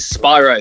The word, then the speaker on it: Spiro: So for the people that don't know, my actual given birth government name is Spyro, but it's spelled S-P-I-R-O.